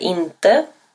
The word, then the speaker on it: inte: Jag är inte. – Ты не есть. – Du är inte. – Он, она не есть. Или же оно это не есть. – Han är inte.